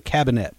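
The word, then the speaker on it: Kabinett: And the first step is what's called a cabinet, which is literally means cabinet. The Germans thought that... – Cabinet starts with a K and two T's. Other than that, it's spelled just like it sounds.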